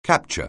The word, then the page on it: capture: anglais